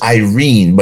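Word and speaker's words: Irene (εἰρήνη): The Greek word in that phrase it's pronounced Irene, but it's spelled with an E before the Irene. So E-I-R-E-N-E, from which we get the name Irene.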